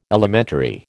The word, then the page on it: elementary: American English